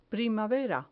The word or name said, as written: primavera